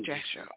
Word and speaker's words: Jasher: J-A-S-H-E-R. – Jasher. Okay. – Mm-hmm. It's actually with a Y. Jasher. – Oh, okay. Jasher. Okay. – Jasher. – Okay. – But yeah, it's the book of Jasher.